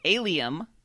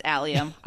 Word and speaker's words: allium: And I went and I listened to pronouncers. No, it's allium.